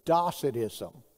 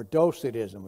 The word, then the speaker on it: Docetism: Docetism, or Docetism, if you like that pronunciation better.